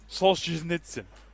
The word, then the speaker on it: Solzhenitsyn: No, this is a tricky one to pronounce. Looking for Solzhenitsyn. – Come on, dude. Because they didn't give the sh, right? The sh, Solzhenitsyn.